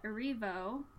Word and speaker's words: Erivo: But, in the same vein, I just read that Cynthia Erivo, is that how you pronounce it? – Yeah.